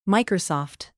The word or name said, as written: microsoft